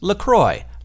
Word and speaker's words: LaCroix: So I also thought that LaCroix was pronounced LaCroix because it's got all of those extra letters in it, so I figured that it was French. But in fact, LaCroix is made in America by the St. Croix River, which is spelled C-R-O-I-X, and is pronounced Croix. So, yep, it's American, it's not French. And they say on the website, it says right there, it's pronounced LaCroix, like enjoy. Apparently.